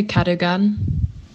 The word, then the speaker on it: Cadogan: I don't really know because it's a name, but I would say Cadogan. – Ja, also so wird es ausgesprochen.